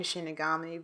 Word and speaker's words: Shinigami: I like how you're saying Shinigami because that's the right way to say it, but they had to call it Soul Reapers in English because Death Note was coming out at the exact same time.